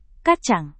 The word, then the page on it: kacang: Indonesian